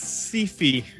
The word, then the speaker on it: Syfy: It's pronounced Sci-fi. – All right. – S-Y-F-Y.